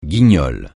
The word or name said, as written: guignol